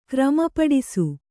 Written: ಕ್ರಮಪಡಿಸು

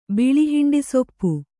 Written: ಬಿಳಿಹಿಂಡಿಸೊಪ್ಪು

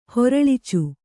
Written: ಹೊರಳ್ಚು

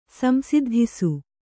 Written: ಸಂಸಿದ್ಧಿಸು

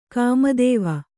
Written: ಕಾಮಿತ್ವ

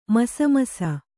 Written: ಮಸಮಸ

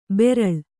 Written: ಬೆರಳ್

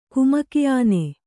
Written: ಕುಮಕಿಆನೆ

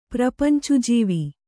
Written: ಪ್ರಪಂಚುಜೀವಿ

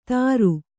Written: ತಾರು

